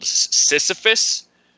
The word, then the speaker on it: Sisyphus: It's a Greek myth called Sisyphus.